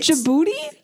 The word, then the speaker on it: Djibouti: Djibouti. – I'd have to Google it. – That's how you say it. – Djibouti? – Yes.